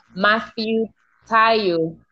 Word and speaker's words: Matthew Tayo: This is a session I'll be having with Matthew Tayo.